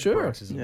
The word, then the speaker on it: paroxysm: Paroxysm. – That's yours, Marcus. – Yeah, is that how you say it? – Paroxysm. – Yeah. I think it's paroxysm.